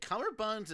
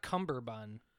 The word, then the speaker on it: Cummerbund: It's not Cumberbund. – I've been saying... – It's Cummerbund. – Cummer... Really? – Right.